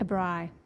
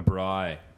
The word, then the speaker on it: braai: A braai. – A braai. Which is spelled B-R-A-A-I. – That's correct.